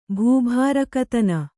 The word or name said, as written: ಭೂಭಾರಕತನ